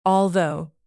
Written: although

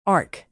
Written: ark

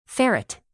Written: ferret

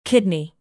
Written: kidney

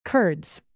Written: curds

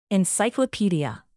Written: encyclopedia

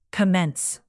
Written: commence

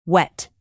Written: wet

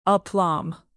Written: aplomb

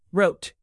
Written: wrote

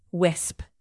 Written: wisp

